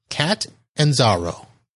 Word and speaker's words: Catanzaro: So, Catanzaro.